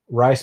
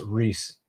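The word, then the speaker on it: Rhys: Rice McKee is pronounced Reese, just for the future, brother.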